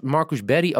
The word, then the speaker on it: Marcus Berg: Werd Marcus Berry ook altijd zo in Zweden genoemd? – Ja, want zo spreek je zijn naam uit in het Zweeds.